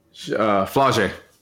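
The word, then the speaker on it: Flau'jae: Flaje. – Flaje. Spell it. – F-L-A-U apostrophe J-A-E.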